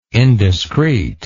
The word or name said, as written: indiscrete